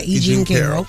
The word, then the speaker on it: E. Jean Carroll: The person who alleges that he assaulted her is E. Jean Carroll. Am I saying that right? – Yeah, E. Jean Carroll.